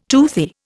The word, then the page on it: toothy: Indian